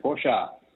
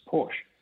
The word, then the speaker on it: Porsche: Correct, Porsche. And the reason is that there's no silent letters in German, so they pronounce the E. In English, we have usually drop the E or don't pronounce the E. So when you read the word, for us, it reads as Porsche. And that's why America, Australia, all those places call it Porsche, but it's actually Porsche.